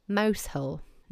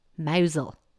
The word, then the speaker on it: Mousehole: Then we have a Cornish town by the name of what I would say Mousehole. No, it's pronounced Mousel. And what about that place that looks like it's Bychester?